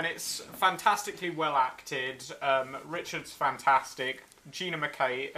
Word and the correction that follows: McKee: uh, McKee is fantastic, and Angie.